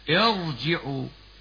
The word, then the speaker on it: ارجعوا: فإذا كان الحرف المكسور قبل الراء ليس أصليا فتفخم الراء كما ننطقها كذا: ارجعوا إلى أبيكم، ارجعوا، فخمنا الراء وإن كانت ساكنة بعد كسر لوقوعها بعد همزة وصل،